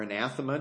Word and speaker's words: anathema: That word anathema, or anathema, depending on how you've learned to pronounce it, but anah, it's the accent is on the at.